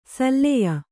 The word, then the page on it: ಸಲ್ಲೆಯ: salleya